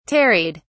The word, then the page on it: tarried: British English